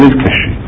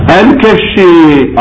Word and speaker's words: الكشي: لكن الشيخ النجاشي ضبطه بالكاف الكشي، والقراءة الثالثة بالفتح لكن مع التخفيف الكشي.